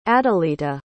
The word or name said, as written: adelita